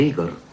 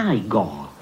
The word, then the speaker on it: Igor: Tu devi essere Igor. – No, si pronuncia Igor.